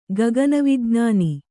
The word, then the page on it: ಗಗನವಿಜ್ಞಾನಿ: gagana vijnāni